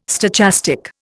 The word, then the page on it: stochastic: Indian